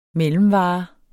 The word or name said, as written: mellemvare